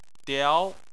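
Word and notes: diao: tone 2

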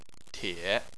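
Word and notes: tie: tone 3

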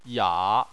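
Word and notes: ya: tone 3

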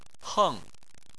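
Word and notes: heng: tone 4

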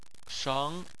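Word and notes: sheng: tone 2

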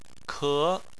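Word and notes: ke: tone 2

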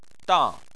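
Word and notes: dang: tone 4

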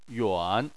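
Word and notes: yuan: tone 3

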